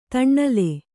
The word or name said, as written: ತಣ್ಣಲೆ